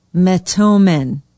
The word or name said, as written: metomen